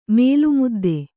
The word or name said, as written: ಮೇಲ್ಮುದ್ದೆ